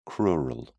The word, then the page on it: crural: British English